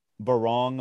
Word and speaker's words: Barong: Just, I guess just to wrap up, you know, the bookends of the essay, the first one is Barong. Am I pronouncing that correctly? – Yeah.